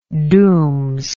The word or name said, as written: dooms